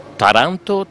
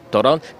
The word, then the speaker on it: Taranto: Partecipando ai vari festival internazionali parlavo di Taranto, del nostro festival, e quindi magari con la pronuncia gli stranieri dicevano Taranto, Toronto, pensavano che fosse in Canada il festival,